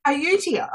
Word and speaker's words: Ayutthaya: Something like Ayutthaya. – Ayutthaya. – Oh, well done.